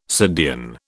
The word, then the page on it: subdean: British English